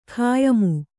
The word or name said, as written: ಖಾಯಮ್ಮು